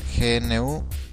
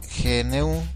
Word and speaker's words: GNU: qué significa GNU, es Is Not Unix y se pronuncia igual a New.